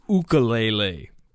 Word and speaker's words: ukulele: And he was talking about it's pronounced ukulele. So I've been pronouncing it wrong this whole time.